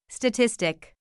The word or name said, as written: statistic